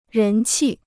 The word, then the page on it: 人气: rén qì